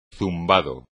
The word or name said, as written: zumbado